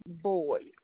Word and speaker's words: Boyd: It's Margaret Boyd, B-O-Y-D. – So that's your first name as well. – Margaret, M-A-R-G-A-R-E-T.